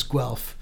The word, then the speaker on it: Guelph: And I'm, you know, probably one of the few Physics World readers who actually knows how to pronounce Guelph properly. That's where Donna Strickland, it's the next town over from Waterloo. – Hang on, how do I pronounce it? Guelph. – Guelph. – Guelph. – Guelph. It's Guelph.